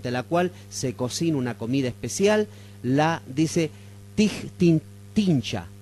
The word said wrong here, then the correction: tincha: tincha, perdón, la pronuncio bien, la tincha